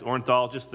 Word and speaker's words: ornithologist: Now, this was an ORI, Orinthian, the bird specialist. They just, it's a myth. Ornithologist, thank you.